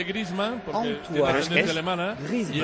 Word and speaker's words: Griezmann: Juan Griezmann. – No, si por mucho me lo pongas, o sea, lo puedes poner hasta el día del año nuevo. – Está bien pronunciado y con cariño.